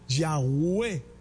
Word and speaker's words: Yahweh: Donde está la I, se le agregó la letra E intermedia y entre la H y la W, la O, y entre la W y la H, la A, que si tú ya, ahí sí ya se podía pronunciar Yahweh, que en las Biblias ya viene traducido como Yahvé.